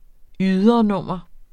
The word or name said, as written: ydernummer